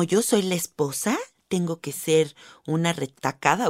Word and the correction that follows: recatada: o cómo se dice esa palabra? Recatada. – Recatada. retacada, no. Retacada, no. No, es recatada.